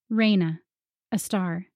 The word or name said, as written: Reyna